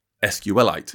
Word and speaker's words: SQLite: Richard Hipp, the creator of SQLite, who told me, by the way, it's a fun fact for listeners, that SQLite he pronounces as a mineral. He pronounces it as SQLite. – What? – Like, you know, carbonite or similar.